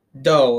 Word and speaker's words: dough: And then there's dough, like D-O-U-G-H, spelled the same as rough, tough, and cough. – But it's not duff. – Like, you don't go, oh, I want a duff nut. You say, I want a dough nut.